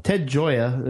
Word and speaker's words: Ted Gioia: Ted Gioia.